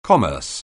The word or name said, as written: commerce